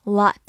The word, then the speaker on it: lot: lot，美式发音 lot，代词、限定词、副词、名词，大量、许多。